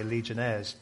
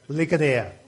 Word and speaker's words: Ligonier: I want to say Legionnaires, but it is that word, right? Ligonier, okay.